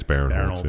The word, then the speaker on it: Barinholtz: I think it's Barinholtz.